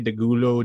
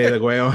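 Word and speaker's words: Degüello: So I went with I'm Bad, I'm Nationwide, released off of their Deguayo album, depending on how you want to say it, Degulo, Deguayo. – Latinos, say it right. – We're Latinos, so we got to say Deguayo.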